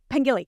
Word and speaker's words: Pengilly: I want to make sure I pronounce his name. Uh, Pengli? Pengilly? Pengilly. Okay.